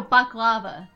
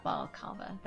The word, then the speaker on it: baklava: No, baklava, not balakava. That makes no sense. I wanted to clarify. Baklava. – Oh, so they're just putting the wrong emphasis on the wrong syllable.